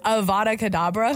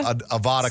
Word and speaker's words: Avada Kedavra: How do you say it? – Avada Kedavra. – Avada Kedavra.